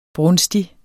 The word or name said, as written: brunstig